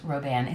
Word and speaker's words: Roban: It's Robin, R-O-B-I-N, but it's pronounced Roban. It's French.